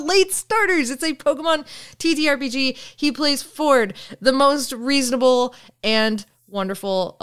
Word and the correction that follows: Fjord: And it's not how you pronounce it. – Pronounce what? Oh, Ford? Sorry, Fjord.